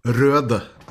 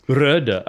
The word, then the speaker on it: Røde: That's appropriate for, you know how we pronounce it in Norway? – Yeah, how do you pronounce it? – With Røde. – Røde, okay. – Because it has the Norwegian letter, what the English speaking people tend to, I see, they think it's just the typography, but it's actually, it's a different letter.